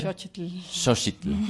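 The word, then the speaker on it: Xóchitl: Xochitl.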